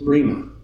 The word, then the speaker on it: rhema: This word is rhema. R-H-E-M-A, rhema, or pronounced rhema.